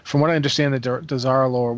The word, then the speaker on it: Dazar'alor: I want to say Darzalore. Is that how you pronounce that? – Darzalore. I don't know. – Dazar'alor.